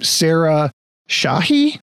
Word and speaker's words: Sarah Shahi: And then you've got, and I'm gonna totally butcher this name, Sarah Shahi? – Probably right. – Yes. Yes, that is correct.